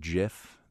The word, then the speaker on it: GIF: Yes, I pronounced that GIF. That is the proper way, but that's a whole different final.